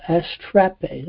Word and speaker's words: astrape: Astrape, I'm not sure, let's see, astrape, that's how you pronounce it, lightning, or bright and shining, to glare.